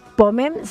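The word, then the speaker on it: pomme: Ah, bien, ahí nos dice, pomem se acentúa en la O y la E no se pronuncia.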